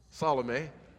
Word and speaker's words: Salome: Say Salome with me. Salome. That's right. I Googled how to pronounce it. That's what they told me.